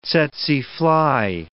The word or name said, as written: tsetse fly